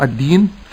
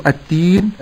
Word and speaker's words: الدين: أما لفظ الدين فلا نقول التين بقلب الدال تاء، فبعض التالين لكتاب الله يقول التين ولا يقول الدين.